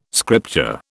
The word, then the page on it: scripture: British English